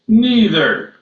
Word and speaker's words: neither: 意思就是现在问题是呢，就是说是你怎么去发那个倒数第二行的第一个词呢？And some would say that word is neither.